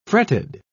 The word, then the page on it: fretted: British English